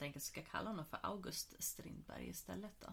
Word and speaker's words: August: Men August kommer från Augustus. och ska uttalas som Augustus.